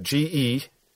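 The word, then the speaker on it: ge (γῆ): Spell that G-E. You can put a line over the E that it's a long E, so it's pronounced ge.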